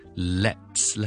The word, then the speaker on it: let's: Let's 咧，佢嗰个发音咧系佢分辨唔到嘅。大家可能会觉得，喂，好似两个完全唔同嘅字嚟嘅，点会分辨唔到咧？其实两个字咧个发音只系一个非常非常之细微嘅分别，就系咧，当我读 less 咧，我系直接由 le 去到 s less less less，中间咧系冇 airflow 嘅停顿过嘅，而 let apostrophe s 咧。